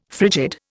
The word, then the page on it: frigid: Indian